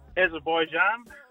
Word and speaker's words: Azerbaijan: Azerbaijan.